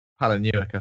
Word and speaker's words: Palahniuk: uh, Easton Ellis or Palahniuk? That's the one I Palin- don't know how to pronounce. Palahniuk, I think. – Palahniuk. Yeah. Okay.